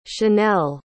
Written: shannelle

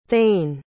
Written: thein

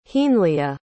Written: heanleah

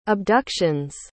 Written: abduction's

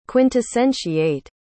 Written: quintessentiate

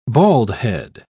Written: baldhead

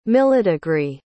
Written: millidegree

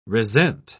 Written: resent

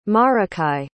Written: maracay

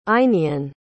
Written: einion

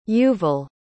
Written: yuval

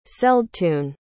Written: celdtun

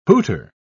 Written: hooter

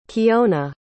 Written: keona

